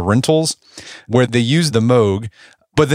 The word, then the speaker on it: Moog: Is that how you pronounce it? Moog or Moog? – I think it's Moog. – Moog.